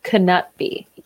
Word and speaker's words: Knutby: I listened to some actually Swedish people talking about the case, and they called it Knutby. I'm sorry, Knutby. – Knutby. – They pronounced it. – Okay. Well, that's better. Okay. That's much better.